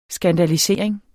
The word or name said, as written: skandalisering